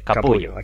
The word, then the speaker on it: Capullo: Bueno, no sé si se dice Capullo, pero se escribe Capullo. – Sí, Capullo.